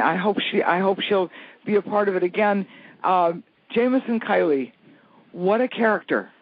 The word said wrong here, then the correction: Keeley: Did I pronounce his name? – Keeley. Uh-huh.